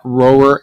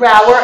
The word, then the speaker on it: Rower: We pronounce it Roer LLC, but I'll take it.